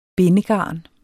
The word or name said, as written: bindegarn